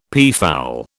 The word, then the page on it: peafowl: British English